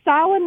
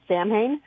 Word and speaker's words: Samhain: He's currently in production on a fantasy thriller called The Town of, I'm going to say Samhain, which is the correct pronunciation, but I can almost guarantee that they're pronouncing it Samhain.